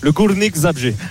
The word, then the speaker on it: Górnik Zabrze: Belle prononciation d'ailleurs pour le Górnik Zabrze.